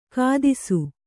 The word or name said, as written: ಕಥಿಸು